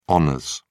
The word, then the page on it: hons: British English